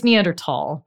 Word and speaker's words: Neanderthal: It's Neanderthal, because it, even when it was customarily spelled with an H, it wasn't pronounced in German. – Neanderthal? – Yes, tall. And it's because tall means valley in Germany, and the name comes from the Neander Valley in Germany where their bones were first found.